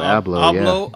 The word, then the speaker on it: Abloh: Virgil Abloh.